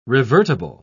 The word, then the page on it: revertible: British English